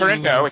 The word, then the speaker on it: Turandot: It's Turndot. It's a silent T at the end.